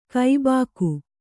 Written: ಗಬ್ಬಿಗೆ

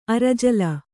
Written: ಅರಜಲ

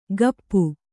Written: ಕಪ್ಪು

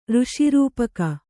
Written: ಋಷಿರೂಪಕ

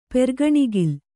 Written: ಪೆರ್ಗಣಿಗಿಲ್

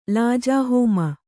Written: ಲಾಜಾಹೋಮ

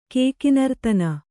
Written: ಕೇಕಿನರ್ತನ

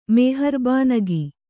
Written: ಮೆಹರ್ಬಾನಗಿ

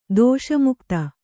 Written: ದೋಷಮುಕ್ತ

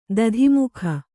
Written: ದತ್ತುಮಗ

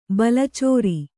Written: ಬಾಲಚಾರ

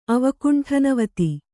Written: ಅವಕುಂಠನವತಿ

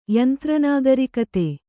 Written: ಯಂತ್ರನಾಗರಿಕತೆ

